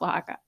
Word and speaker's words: Oaxaca: Oh, like Jen thinks it's really weird that Oaxaca is pronounced Oaxaca. Like there's an X in there that should not be Oaxaca. – It's O-X-A-C-A, right? – O-A-X-A-C-A.